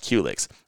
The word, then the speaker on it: Qulyx: Q-Licks is spelled Q-U-L-Y-X. pronounced Culix.